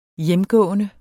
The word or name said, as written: hjemgående